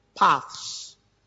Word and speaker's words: paths: P-A-T-H. P-A-T-H-S. Okay? Straight paths. I don't know how those words are pronounced. Right? Path. There's a path, and you have to release your tongue and say, shh.